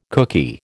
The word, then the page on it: kooky: American English